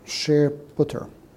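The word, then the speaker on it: shared_ptr: These are pronounced SharePutter.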